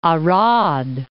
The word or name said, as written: Arad